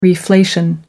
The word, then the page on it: reflation: American English